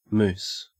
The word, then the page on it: moose: Australian English